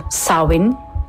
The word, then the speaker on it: Samhain: Por último, temos o Samhain, ou pronunciado Samhain, é assim que se diz.